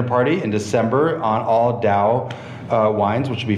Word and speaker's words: DAOU: DAOU, did I say that right? – I think so. DAOU yeah.